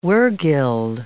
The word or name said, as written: wergild